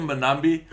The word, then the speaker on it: Mbanambi: At hooker, he's gone with Bongi Banambi and Franz Malerby. Tell me what I butchered. – All of it. Tendai Tabariria. Oh my goodness, I can't say it either now. Bongi Mbanambi and Franz Malherbe.